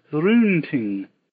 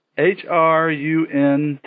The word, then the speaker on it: Hrunting: Hrunting is the name of Beowulf's sword. Hruntiing. Hrunting? – That's not bad, actually.